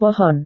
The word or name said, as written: pohon